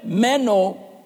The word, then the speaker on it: meno: Made up of two words, the word H-U-P-O, hupo, and the word M-E-N-O, meno, or meno. No, it's Greek, I said meno. The word hupo means under, or to be underneath something. And the word meno, or meno, it depends who you like, okay.